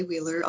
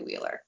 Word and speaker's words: Wheeler: And my name is spelled K-E-L-L-Y, last name W-H-E-E-L-E-R. So on Instagram, it's at Dr. Kelly Wheeler, all lowercase.